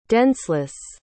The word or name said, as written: denseless